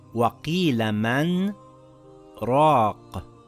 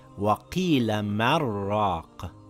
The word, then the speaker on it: مَنْ رَاقَ: I took a slight pause after the word man, and without taking another breath, then I continued reading. It is not pronounced وَقِيلَ مَرَّاقَ with the يَرْمَلُون rule, as explained in previous lessons.